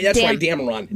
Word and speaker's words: Dameron: Daveron. – Poe Daveron. – With M. Is the... – Oh, that's right. Dameron. Dameron. – Like cuss word, Aron. Yeah.